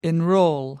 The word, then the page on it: enrol: American English